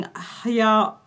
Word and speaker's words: hyaluronic: So according to new research, they've been injecting hyaluronic. How do I say that? – Hyaluronic acid.